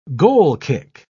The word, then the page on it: goal-kick: British English